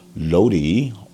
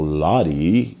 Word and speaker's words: Lodi: This is an Asheville Cellars from Lodi or Lodi, California, 2017. I keep getting my behind chewed out. You're saying that wrong. So I'm going to say the Lodi, California.